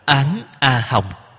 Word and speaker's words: Án A Hồng: đọc là Án A Hồng.